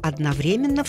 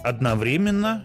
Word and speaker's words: одновременно: Там оно дается так, как я его произнесла. Русский орфографический, большой орфоэпический, большой толковый и словарь трудности русского языка разрешают два ударения. – Одновременно и одновременно.